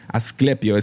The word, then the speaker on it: Asclepio: Asclepio, es A-S-C-L-E-P-I-O, Asclepio.